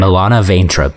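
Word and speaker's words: Milana Vayntrub: Milena Vaintrub. Vaintrub. How do we say that? – What's her Milana, name? Milana Vaintrub, yeah. – Okay, Milana Vaintrub.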